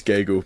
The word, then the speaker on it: Gagel: It's Gagel, but don't worry about Gagel. – It. – Like bagel.